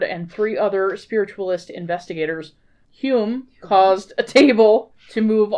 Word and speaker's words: Hume: It's spelled, it looks like home, but it's actually Hume.